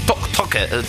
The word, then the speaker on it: Taake: I això, bueno, diu Noruegan Pronunciation, Toke, es deuen dir. Bueno, Take s'escriu amb T-A-A-K-E.